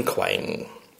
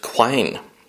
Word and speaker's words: quine: It's like an Aberdeenshire word, and it's pronounced quine.